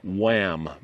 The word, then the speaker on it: WAAAM: Today we are at the Wham Museum in Hood River, Oregon. That's spelled W-A-A-A-M, and it stands for Western Antique Airplane and Automotive Museum.